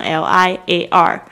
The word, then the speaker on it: liar: Number six， liar，这个字呢看起来很像是英文的 liar，其实拼法是一模一样， liar， liar，这个是西语的发音， liar 是撒谎的人，说谎的人，所以叫 liar，但是其实呢它的真实意义是 roll，就是卷，把什么东西卷起来， liar。